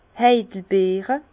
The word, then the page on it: d' Heidelbeere: pl.; Bas Rhin; Herrlisheim